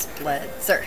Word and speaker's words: Spletzer: Spletzer.